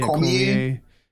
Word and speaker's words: Cormier: And then there's also a rumor that Cormier might cost Brock. Yeah, Cormier. It's a silent R. It's French.